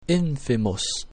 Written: infimus

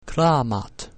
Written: clamat

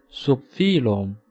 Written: subphylum

